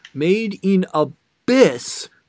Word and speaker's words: Made in Abyss: And also the Made in a Miss... Made in a... I keep mispronouncing this episode. I don't know why. I said Made in a Miss. Made in Abyss...